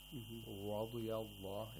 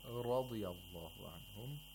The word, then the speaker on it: radiallahu: And it should not be like an English R. Radiallahu is incorrect. It's not radi. – We need the tapping sound, as you said. – Radiallahu anhum.